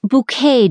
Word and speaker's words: Bucket: Imagine, if you will, a cross between Queen Victoria and Hyacinth Bucket. It's pronounced bouquet, dear, the fussy social-climbing character on the Britcom Keeping Up Appearances constantly reminds people.